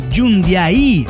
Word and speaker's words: Jundiaí: Rastadog de Jundiaí, Jundiaí.